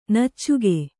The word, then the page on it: ನುಚ್ಚಕ್ಕಿ: nuccakki